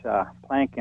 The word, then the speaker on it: Planck: and that report comes from the Max Planck Institute. Forgive me if I have the pronunciation wrong. But Planck, you got it.